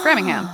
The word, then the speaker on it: Framingham: Framingham. – Yeah, Framingham. – Framingham. But it's one M, so technically it should be said Framingham.